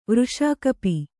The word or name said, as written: ವೃಷಾಕಪಿ